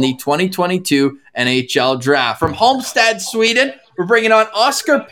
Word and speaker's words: Halmstad: Last pronunciation, Halmstad? – Yeah.